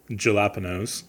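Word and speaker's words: jalapeños: Oh, well, you got to pronounce it like we do in the Midwest. Jalapenos.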